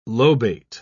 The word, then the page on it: lobate: British English